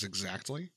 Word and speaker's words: exactly: But one that bothers me is exactly. – Yeah, I don't like it. I always pronounce the T in exactly. I hate it when people don't. – Exactly. – There's a fucking T there. Exactly.